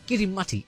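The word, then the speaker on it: Kiritimati: When translated from the local Gilbertese language, Kirimati is simply how the locals pronounce Christmas.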